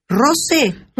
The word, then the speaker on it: Rose: Se llama Elena Rosé, creo que es. – ¿Rosé? – Sí. – Porque se escribe Rose, sí, o sea, R-O-S-E. – Creo que la presentan como Elena Rosé.